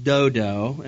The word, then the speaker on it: Dodo: It's bad enough telling people you're the son of Pua, even worse to tell them that your grandfather's name is Dudu. No, actually, it's pronounced Dodo, and I apologize, that's the second straight message where I said Dudu.